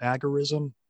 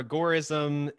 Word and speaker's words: agorism: Uh, so, agorism or agorism, it's pronounced both ways depending on who's saying it. I say agorism just because it is derived from the Greek word agora, and that's kind of the root word behind it.